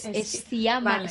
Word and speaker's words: Sciamma: Celine Sciamma.